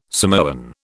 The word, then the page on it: Samoan: British English